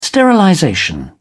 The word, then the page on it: sterilization: British English